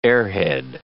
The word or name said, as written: airhead